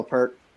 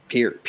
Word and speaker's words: Peart: Keep ribbon in heaven, Neil Peart. Peart. Jesus, I did it. – Close. – Peart, Peart, Peart, Peart. I gotta drill that into my brain because I keep saying it wrong.